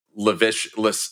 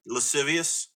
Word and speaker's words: lascivious: and lewd and lascivious. How do you pronounce that? It's lascivious. – Yeah, lascivious. Thank you.